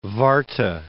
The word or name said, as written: Warthe